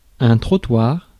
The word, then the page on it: trottoir: France; [tʁɔ.twaʁ]; French